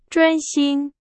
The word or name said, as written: 专心